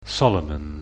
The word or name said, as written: Solomon